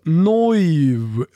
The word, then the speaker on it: Neuw: N-E-U-W, alltså Neuv blir det på tyska, så det är det som stör mitt österrikiska påbrå här.